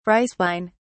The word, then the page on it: rice wine: British English